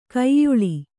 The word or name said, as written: ಕೋಯಿಲು